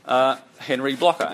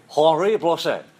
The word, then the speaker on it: Henri Blocher: Here's a quote from Henri Blocher. Actually, he's French, so I can't say it like that. Who studies French? – Henri Blocher. – Henri Blocher. There you go.